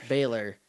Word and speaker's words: Balor: Balor. But it's B-A-L-O-R, which is Balor.